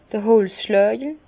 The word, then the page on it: d'r Holzschlägel: Bas-Rhin; Herrlisheim